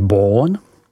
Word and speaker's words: Beaune: Je vzdálená asi 7 kilometrů od města Beaune, vyslovuj Beaune, a zde se připravují vyhlášená vína statutu Grand Cru, o kterém víme, že si stojí mezi burgundskými víny nejvýše.